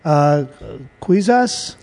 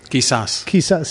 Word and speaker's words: Quizás: But a couple of the ones that jumped out to me was Quizás. – Quizás. – See, I knew I was going to say that wrong. Quizás.